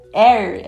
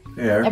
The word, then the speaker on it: Air: É, e, e eu queria falar um pouco sobre o Air. É assim que se pronuncia? – É. – Air.